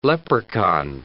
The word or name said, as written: leprechaun